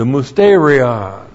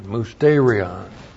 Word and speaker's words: musterion: The Musterion. M-U-S-T, long E-R-I-O-N, Musterion.